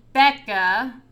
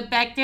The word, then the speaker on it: Beckah: So on Twitter, I am at Mary Becca, but the Becca is spelled with a K. So it is B-E-C-K-A-H.